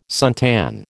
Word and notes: suntan: American English

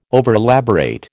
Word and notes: overelaborate: American English

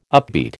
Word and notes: upbeat: American English

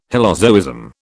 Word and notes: hylozoism: British English

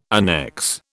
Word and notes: annexe: British English

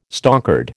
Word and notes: stonkered: American English

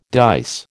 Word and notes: dice: American English

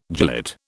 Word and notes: gilet: British English

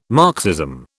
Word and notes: Marxism: British English

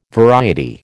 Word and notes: variety: American English

